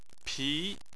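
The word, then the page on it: pi: tone 2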